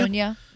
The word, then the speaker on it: Patagonia: Patagonia? Is that how you say it? Patagonia? – Patagonia. Mm-hmm.